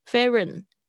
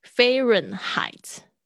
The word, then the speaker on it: Fahrenheit: 对啊，飞飞，然后 H 不发音呢，飞轮轮 Fahrenheit，这边是发 AI 的声音，这个字可能是德文来的 Fahrenheit。